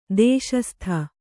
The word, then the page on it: ದೇಶಸ್ಥ: dēśastha